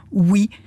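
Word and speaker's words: ouïe: Je me suis dit, tiens, c'est bizarre ce mot, oui. Ça se prononce comme le oui de oui ou non, mais ça s'écrit de manière alambiquée, avec un mignon tréma sur le i et puis un e muet final.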